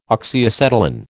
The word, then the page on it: Oxyacetylene: American English